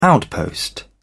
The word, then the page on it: outpost: British English